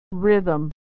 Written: rhythm

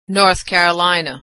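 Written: North Carolina

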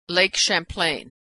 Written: Champlain

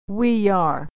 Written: we are

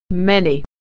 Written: many

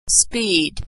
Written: speed

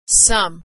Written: some

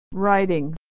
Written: writing

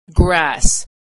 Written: grass

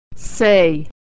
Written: say

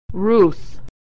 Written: Ruth

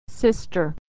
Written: sister

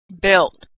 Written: built

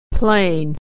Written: plane

